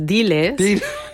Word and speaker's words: Diles: Dimash heißt das Stück. Habe ich es richtig ausgesprochen? – Nee, hast du falsch. Nein, falsch. Das heißt Diles.